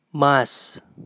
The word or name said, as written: Mas